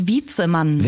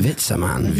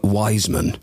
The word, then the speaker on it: Witzemann: How do you say it, the venue? – Witzemann. – Witzemann. – Witzemann. – I call it I'm Wiseman. – Yeah. – So English.